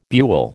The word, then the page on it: buhl: American English